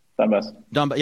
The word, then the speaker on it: Donbas: I was reading how Putin went on TV and local, and he was saying that is it Donbas? Is that how you pronounce the region? – Donbass.